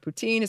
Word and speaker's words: poutine: First, in French, or at least Quebec French, the word poutine is pronounced the same as the ruler poutin.